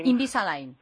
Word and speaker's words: Invisalign: Quería hablar del Invisalign. ¿Cómo se dice exactamente? Invisalign, eso.